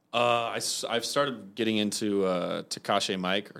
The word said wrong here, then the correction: Takashi Miike: or Takashi. – Takashi Miike? – Takashi Miike. – Yeah. – Pronounced it wrong.